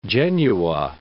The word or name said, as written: Genua